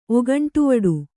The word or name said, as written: ಒಗಂಟುವಡು